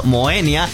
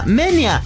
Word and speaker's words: Moenia: Y aunque todos decimos Moenia, realmente se pronuncia Menia.